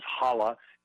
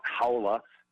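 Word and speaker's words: Haula: Eric Halla's name is Halla. It's not Howla, as it's been pronounced by some people.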